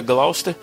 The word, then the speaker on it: Gloucester: é uma, uma cidadezinha chamada Gloucester, e não estranha aí porque se escreve Gloucester, mas a pronúncia lá, segundo eles, é Gloucester.